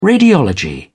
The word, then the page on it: radiology: British English